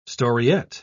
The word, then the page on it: storiette: British English